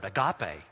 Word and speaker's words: agape: We've taken that Greek word and pronounced it out just as it's pronounced in the Greek language, and we've made it an English word, agape. We say it just like that.